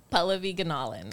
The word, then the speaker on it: Pallavi Gunalan: Pallavi Gunalan. – Gunalan. What? Okay. I say like Norlins, like Nolan.